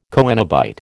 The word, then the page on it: coenobite: American English